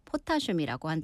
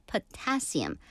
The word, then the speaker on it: potassium: 칼륨도 마찬가지로 potassium, 포타슘이라고 한다고 하네요. 영어 발음은 potassium.